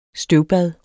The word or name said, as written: støvbad